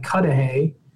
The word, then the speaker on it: Cudahy: I was assigned to go to a very nice little lakefront city called Cudahy. I believe that is how they pronounce it there.